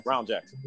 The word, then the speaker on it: Brown Jackson: We're going to talk about Ketanji Jackson Brown. Hmm. Or am I saying that right? Is it Brown Jackson? – Brown Jackson.